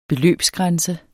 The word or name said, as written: beløbsgrænse